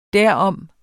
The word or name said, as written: derom